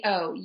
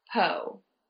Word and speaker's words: Yotpo: Yep, it's Yotpo, Yotpo.